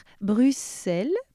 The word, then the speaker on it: Bruxelles: on doit prononcer Auxerre, Bruxelles, puisqu'il s'écrivait en néerlandais Bruxelles avec deux S, E et L.